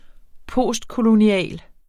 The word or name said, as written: postkolonial